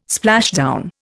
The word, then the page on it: splashdown: Indian